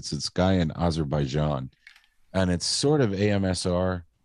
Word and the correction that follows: ASMR: Is that how you say it or is it ASMR? – ASMR.